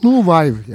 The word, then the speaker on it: Nouvelle: Çünkü muhtemelen doğru telaffuz etmiyorum ama Nouvelleberg Fransızca yeni dalga demek. Yeni Fransız sinemasındaki yeni dalgadan bahsediyoruz. – Nouvelle yani.